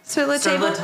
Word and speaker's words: Sur La Table: So is it Sur La Table? Sur le table. – Sur le table. Yes.